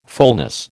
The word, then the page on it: fulness: American English